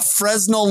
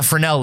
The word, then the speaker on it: Fresnel: A Fresnel lens. So they've divided this project into different stages. – You mean a Fresnel lens? – Is that how you pronounce it? – Yeah, a Fresnel lens.